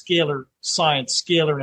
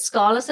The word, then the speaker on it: scalar: scalar energy. – Wow. So I've been saying it scholars, I've been saying it wrong, it's scalar energy.